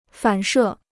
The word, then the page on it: 反射: fǎn shè